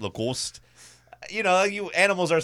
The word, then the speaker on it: Lacoste: I'm fine with it being an actual tiger because, like, you know, horses for polo, you know, alligators for Lacoste or however the hell you say that, Lacoste or – That's Lacoste.